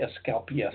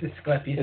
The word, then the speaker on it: Asclepias: I always pronounce that wrong. I always say Asclepias. – It's Asclepias. – It's Asclepias aspirulla.